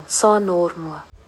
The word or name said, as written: Sinothermo